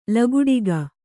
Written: ಲಗುಡಿಗ

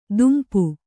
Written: ದುಂಪ

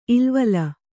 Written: ಇಲ್ವಲ

